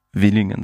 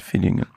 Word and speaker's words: Villingen: Oder Willingen sagt anstatt Villingen. Weil V ist ja ein V spricht man ja als V aus, wie ja. Vogel.